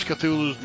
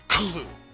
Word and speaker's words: Cthulhu: Actually, nobody ever pronounces Cthulhu's name correctly. They always say Cthulhu. According to H.P. Lovecraft, he's actually pronounced more like a cough. So it's Kulu.